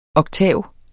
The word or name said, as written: oktav